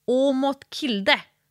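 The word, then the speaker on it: Aamodt Kilde: Ja, och sen fick jag tips av samma tjej som handlar om den norska störtloppsåkaren Åmot Kilde. Uttalas det så, va? – Ja.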